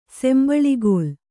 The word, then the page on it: ಸಂಬಳಿಗೋಲು: kannada